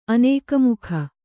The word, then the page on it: ಅನೇಕಮುಖ: Kannada